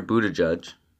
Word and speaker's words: Buttigieg: Peter Buttigieg. And I looked that up two times to make sure I pronounced that right.